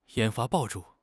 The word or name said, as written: 烟花爆竹